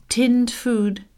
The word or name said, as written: tinned food